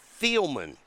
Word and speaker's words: Thielemans: T-O-O-T-S. – Toots. You know we didn't want to hear Toots spelled. – You know, it's like the receiver. Thielman. – Oh, so that's probably what it is, Thielman.